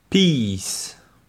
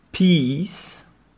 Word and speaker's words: peace: Ça, je vous invite à regarder l'écrit pour bien voir la différence. Peace. Et vous avez PES, qui est de la pisse. PES, Je répète les deux. Peace. Pisse.